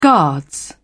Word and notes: guards: British English

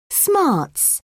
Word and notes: smarts: British English

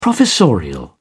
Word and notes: professorial: British English

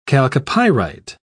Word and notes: chalcopyrite: British English

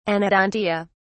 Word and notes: anodontia: British English

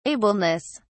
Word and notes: ableness: British English

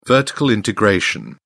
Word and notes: vertical integration: British English

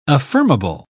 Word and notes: affirmable: British English

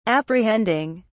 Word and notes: apprehending: British English

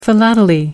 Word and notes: philately: American English

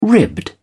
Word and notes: ribbed: British English

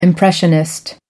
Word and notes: impressionist: American English

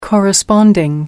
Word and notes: corresponding: American English

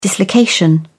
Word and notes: dislocation: British English

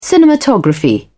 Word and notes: cinematography: British English

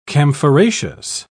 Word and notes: camphoraceous: British English

